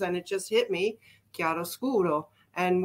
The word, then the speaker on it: chiaroscuro: It's pronounced chiaroscuro, and it's a term used to describe the treatment of light and shade in drawing and painting.